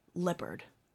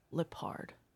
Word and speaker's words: Lippard: Now, did I say it right? Is it Lippard? Lippard. Lippard. Okay.